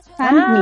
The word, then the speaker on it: Sunmi: Entonces como que Sun es San, ¿cachai? – Sunmi.